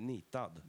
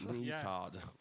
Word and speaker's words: Nitad: Am I pronouncing it right? – Nitad. – Nitad? – Yeah. – Okay, sorry. Yeah. – Like Ni and the name Todd. – Yeah. – Nitad.